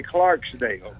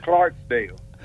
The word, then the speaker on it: Clarkdale: Yeah, you're one of the few guys that didn't say Clarksdale, but it is no S, Clarkdale, right? – Okay, and now I have to say this.